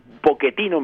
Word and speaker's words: Pochettino: Y, y también eso a nivel plantilla se siente y por ahí viene el fichaje de Mauricio Pochettino, porque me, Pochettino, mejor dicho, porque así lo pronuncian.